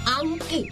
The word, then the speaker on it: อังกฤษ: คำว่าอังกฤษนะเหรอใช่คำว่าอังกฤษต้องออกเสียงควบตัวรอเรือให้ชัดๆสิ